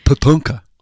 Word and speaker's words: Tatanka: It's Tatanka. That's how you say it. Tatanka.